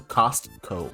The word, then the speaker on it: Costco: Apparently it's pronounced, you're supposed to pronounce the T, so Costco.